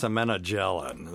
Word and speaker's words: semenogelin: It's semenogelin. That's how it's properly pronounced.